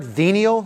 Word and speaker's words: venial: Venial? Did I say that right? V-E-N-I-A-L. You ever heard that word? Venial, say that right? Okay, good.